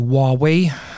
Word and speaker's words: Huawei: ma toon veel ühe näite Läti-Leedi, Läti-Leedu kolleegide pealt, ehm, kus lisaks neile Hiina valvekaameratele pöörati ka mõni aeg tagasi tähelepanu Huawei või Huawei, korrektne hääldus,